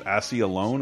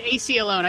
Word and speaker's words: AC Alone: Accepted Eclectic by, Assy Alone. Am I saying that right? – It's AC Alone. I spelled it out specifically.